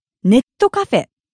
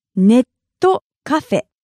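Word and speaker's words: netto cafe (ネットカフェ): In today's lesson, we'll learn about a really useful place, the Internet Cafe.Or as it's known in Japanese, netto cafe. Netto cafe. Netto cafe. As you've noticed, this is simply the Japanese pronunciation of net cafe, but we call it netto cafe.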